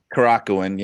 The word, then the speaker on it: Korakuen: Korakuen Hall? – Korakuen, yeah.